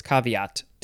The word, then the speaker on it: caveat: You could pronounce this a few ways. Caveat, caveat, caveat. You could say that if you wanted to, but it's caveat to me.